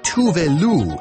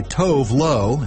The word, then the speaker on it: Tove Lo: The singer says her name would be pronounced Tove Lo in her native Sweden, but everywhere else people just call her Tove Lo, and she's used to it by now.